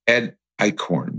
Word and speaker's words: Ed Eichhorn: Today's episode, I have Ed Eichhorn with me.